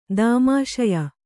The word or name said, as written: ದೌಮಾಷಾಯ